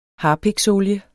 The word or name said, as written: HARPIKSOLIE